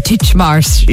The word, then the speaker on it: Titchmarsh: Titchmarsh.